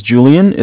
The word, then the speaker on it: Julian: My name is Julian. It's J-U-L-I-A-N.